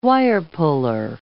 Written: wire-puller

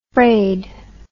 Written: fraid

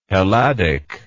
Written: helladic

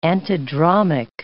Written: antidromic

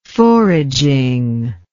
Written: foraging

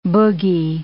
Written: boogie

